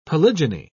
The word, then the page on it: polygyny: British English